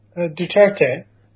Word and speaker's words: Duterte: Can you make sense of what President Duterte, is that how you say his name, Duterte or Duterte? – Duterte.